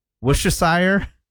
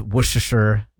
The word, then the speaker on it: Worcestershire: So you don't say the R, you don't say Worcestershire? – No.